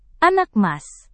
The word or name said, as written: anak emas